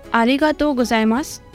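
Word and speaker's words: आरिगातो गोजाइमासु (ありがとうございます): आरेगा तो गुजाइमास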